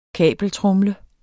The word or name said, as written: kabeltromle